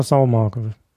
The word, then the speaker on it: Saumagen: Also Kannst du das nicht mal so pfälzisch aussprechen? Pelzer Saumarke. – Marke, genau. – Genau, ohne N hinten.